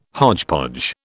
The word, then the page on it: hodgepodge: British English